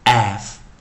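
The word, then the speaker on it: half: have have have have 哦，所以这就 l 不发音。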